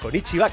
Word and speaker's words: konnichiwa: Konnichiwa. – Konnichiwa, que le gusta decir a, y se dice así.